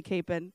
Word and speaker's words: Capon: I feel like if I'm saying it Capon, then you all know I'm not from here. You already knew that. I was told the locals say Capon.